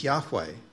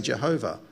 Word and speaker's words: Yahweh: We believe that the best pronunciation of God's personal name is Yahweh. Some people say Jehovah.